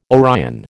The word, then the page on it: Orion: American English